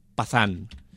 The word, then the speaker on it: Pathaan: Pues bien, pues de los dos protagonistas de Jawan, a mí me recomendaron una película que se llama Pathan, que se escribe con P, de perro. – P-A-T-H-A-A-N, Pathan. – Vale, o sea, no es como el patán del... – No es como el patán del Pierno Doyuna, de los autos locos, no.